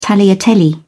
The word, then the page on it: tagliatelle: British English